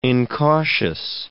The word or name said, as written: incautious